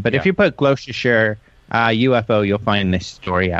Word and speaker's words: Gloucestershire: Well, we actually have someone in the chat room that's actually from Gloucestershire. And that's, well, I just said it the way it's supposed to be pronounced, Gloucestershire.